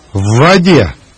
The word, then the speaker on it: В воде: the resulting double consonant is pronounced as one long consonant. Listen, then repeat. – В воде.